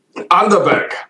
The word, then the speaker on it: Underberg: Underberg.